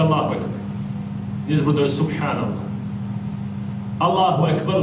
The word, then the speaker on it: Allahu Ekber: I to se izgovara sa slovom K. Allahu Ekber. To je od kebir, velik. El Ekber, najveći. Allahu Ekber. Međutim, često ljudi, pa i oni koji su učeni ili se smatraju takvi, izgovaraju umjesto K, G, i govore Ekber.